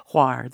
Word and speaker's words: Hvar: They bypass it for that other end of the island that you're talking about, the famous Hvar. Hvar. There's no V. You're not supposed to pronounce it.